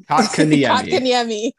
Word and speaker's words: Kotkaniemi: and then The Kotkaniemi, did I pronounce that right? I need to practice. I'm getting my reps in. – Kotkaniemi.